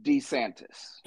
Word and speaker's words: DeSantis: That Chiboni, Juan Santos, whatever that son of a bitch, you can't pronounce his last name, Florida governor. – Yeah, Ron DeSantis.